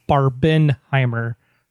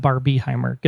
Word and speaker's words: Barbenheimer: It's Barbenheimer. Not Barbieheimer. Get the hashtag right.